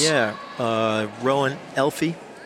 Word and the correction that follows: Elif: as well. Or Elif. I'm sorry, I said that incorrectly the entire time.